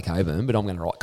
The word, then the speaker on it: Cockburn: Their training base is at Cockburn, as Billy Brownless would say. – I believe it's pronounced Coburn.